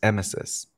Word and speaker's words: emesis: Which is actually pronounced emesis.